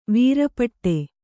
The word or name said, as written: ವೀರಪಟ್ಟ